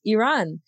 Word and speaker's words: Iran: Is Iran, like E and then run.